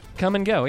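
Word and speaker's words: Kum & Go: And speaking of C-U-M, in Oklahoma, there's a chain of convenience stores called Come and Go, spelled K-U-M and Go. – Come and Go? – Come and Go, yeah.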